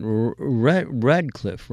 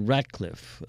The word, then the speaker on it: Ratcliffe: But you keep pronouncing it Radcliffe, right, as opposed to Ratcliffe.